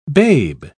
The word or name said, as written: babe